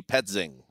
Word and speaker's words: Petzing: Like, Drew Pensing, what a guy. – So did you – okay, so – is that how you say it? – That's P-E-T-Z-I-N-G, Petzing, I would say.